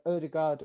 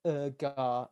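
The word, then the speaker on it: Ødegaard: Odegaard or Urgar, if you want to call him by his actual pronunciation and be a let it down.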